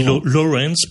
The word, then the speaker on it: Laurence: Est-ce que je le dis bien, Laurence ou... Non, Laurence, on dit Laurence peut-être, Laurence. Mais en français, Laurence Durel, ça passe très bien, à mon avis.